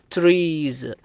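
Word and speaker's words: trees: وبعض الأحيان يأتي في آخر الحرف وينطق بحرف الزاء، مثال عليه كلمة مثلا تريز اللي هي الشجر، جمع شجرة تريز.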